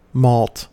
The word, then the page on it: malt: American English